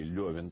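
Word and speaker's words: Лёвин: Появляется Левин, или Левин, точнее, наверное, так, по традиции, люди, знавшие Толстого и его манеру произносить фамилию главного персонажа, называют его.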